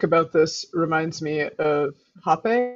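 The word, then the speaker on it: Rapé: But I think it is pronounced rape, but the rapey pronunciation led us here to try and say it differently. But the Taisha that I know that uses it prior to ayahuasca ceremonies, he calls it rape.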